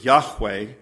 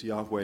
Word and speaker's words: Yahweh: And the word in Hebrew is Yahweh. Sometimes it's pronounced that way. But in the original Hebrew, there's no vowels. So no one is exactly sure what it sounds like.